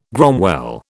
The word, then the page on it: gromwell: British English